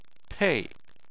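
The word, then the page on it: pei4: tone 4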